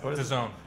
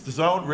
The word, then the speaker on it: DAZN: What is that pronounced? – DAZN. – It's DAZN, really? – Yes. – It's hard to pronounce based upon that.